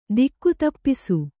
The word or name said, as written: ದಿಕ್ಕುತಪ್ಪಿಸು